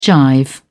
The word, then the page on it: jive: British English